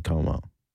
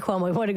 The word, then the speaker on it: Como: I know that because I went to Lake Como. – Oh, right. Lake Cuomo. I said it wrong because I'm poor.